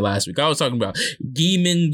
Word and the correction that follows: Jamin: It's Jamin McKinney, y'all.